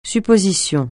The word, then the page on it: supposition: French